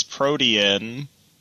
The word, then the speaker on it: protean: And the protein energy and the protein cogs. Is that not the way it's supposed to be pronounced? Protein? – I've always read it as protean.